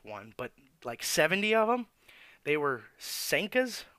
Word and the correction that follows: Seneca: Seneca cigarettes. Hold on. Seneca. S-E-N-E-C-A.